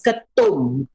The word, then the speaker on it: ketum: In Malaysia, it is pronounced as ketum, ketum, ketum, ketum, ketum.